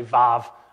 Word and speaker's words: Vav: And so the letters that make it up are uh, the Yod, the He, it's spelled W A W, but it's actually Vav, uh, V A V, and then Hey.